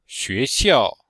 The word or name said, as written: xue2xiao4